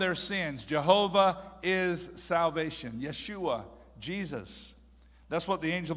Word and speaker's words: Yeshua: Yeshua. Okay, you'll discover when you go to Israel that J's may be seen. You may see the letter J, but they're never pronounced. Jesus is Yeshua.